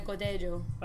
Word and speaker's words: Godello: Also Godello. You've got that as well. – Oh, Godello is how we say it in English, but two L's is actually like a Godello.